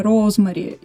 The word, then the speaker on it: Розмари: Я буду говорить «Ребенок Розмари», на самом деле это не совсем корректно. Более корректно, как говорит Саша, именно «Розмари». Именно так произносится скорее на английском в оригинале. Но у нас как-то вот прижилось несколько другое ударение.